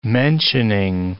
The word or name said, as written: mentioning